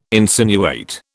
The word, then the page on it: insinuate: British English